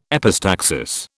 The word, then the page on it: epistaxis: British English